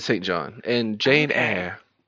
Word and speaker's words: Jane Eyre: Jane Eyre. – Yep. – Wait, what? Did you laugh at the way I said that? – I have known it to be pronounced Jane Eyre.